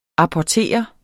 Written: APPORTERES

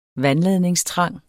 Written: vandladningstrang

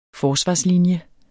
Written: forsvarslinje

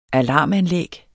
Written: alarmanlæg

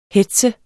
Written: hetz

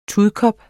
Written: TUDKOP

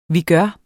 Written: vigør

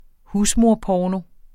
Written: husmorporno

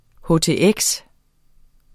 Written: htx

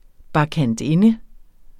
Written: bakkantinde

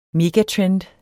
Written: MEGATREND

